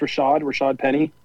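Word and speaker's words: Rashaad Penny: Did you guys see what – is it pronounced Rashad Penny? – Yes.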